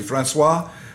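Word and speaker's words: François: And so when we hear the French pronunciation, Francois, and you could see the fur traders, that was huge.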